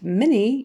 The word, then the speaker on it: many: And many, even though it's spelled M-A-N-Y, we typically say it more like an M-I-N-E-E. So many, many, like Minnie Mouse.